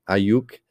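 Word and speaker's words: Aiyuk: Ayuk. – Brandon Ayuk.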